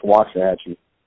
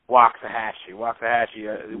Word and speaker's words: Waxahachie: Is that correct pronunciation? – I mean, that's how it's spelled. It's pronounced Waxahachie. – Waxahachie.